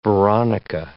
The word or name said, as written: veronica